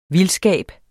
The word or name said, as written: VILDSKABEN